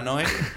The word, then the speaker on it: Neu: que es de Neu, Oye. – Que se pronuncia Noé.